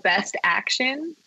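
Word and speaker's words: BST AXN: They have a line called Best Action, but it's spelled BST, Best and then action is AXN, best action.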